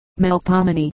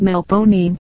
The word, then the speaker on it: Melpomene: Melpomene, instead of being given four syllables, is called Melponine.